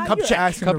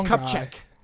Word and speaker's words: Kupchak: Mitch, how do you pronounce his last name? – Mitch Kupchak. – Mitch Kupchak.